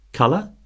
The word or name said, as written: colour